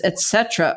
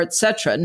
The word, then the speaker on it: et cetera: She always pronounces the second T with a D sound and sometimes the first T with an X sound. Hi, Jen. Just a loving note that it's et cetera or et cetera.